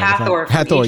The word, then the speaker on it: Hathor: Beyonce, I think it was, I think she caught a lot of flack for using symbolism of, was it Hathor? I hope I'm saying that right. – Hathor, yes.